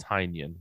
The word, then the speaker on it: Tynion: It's pronounced Tynion.